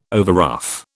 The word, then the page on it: overruff: British English